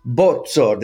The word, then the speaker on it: Bozzo: Excited to have Andy Bozzo. Did I say it right? Did I do it? – Perfetto. Yeah, you did very well.